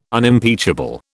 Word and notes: Unimpeachable: British English

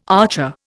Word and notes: archer: Indian

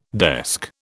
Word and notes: desk: British English

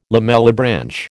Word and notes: lamellibranch: American English

